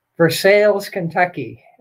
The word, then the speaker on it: Versailles: And I went to a town hall meeting he held in Versailles, Kentucky. That's the Kentucky way of pronouncing Versailles.